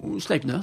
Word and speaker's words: Sleipner: C'est le premier site qui a vu le jour en 1996, qui est le site de Sleipner, je ne sais pas si je prononce bien. – Sleipner, c'est Sleipner. – Ça, tout à fait.